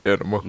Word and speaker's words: Anamma: A-N-A-M-M-A. – Anima.